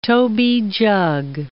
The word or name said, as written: toby jug